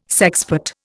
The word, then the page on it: sexpot: Indian